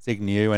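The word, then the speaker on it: Zbigniew: So your first name is pronounced Zygniew? – Yeah. – Yeah, and so... – Zygniew. – And so there's... The correct one is pronouncing... The correct spelling is Z-B-I-G-N-I-E-U. But then... – N-I-E-W. – I meant to say W.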